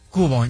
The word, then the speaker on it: Kubo: Takefusa Kubo.